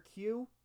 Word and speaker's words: queue: Q is literally just the letter Q, U-E-U-E. You don't pronounce anything past the Q. You're just saying the letter, the first letter. The other four letters are not necessary in any shape, way, or form.